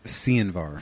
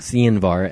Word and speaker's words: Sianvar: First of which, we're going to be getting, we got news that Cianvar I don't know if that's even the correct pronunciation. – I believe it is Cianvar. – Cianvar, S-I-A-N-V-A-R, which is the other side project of Will Swan.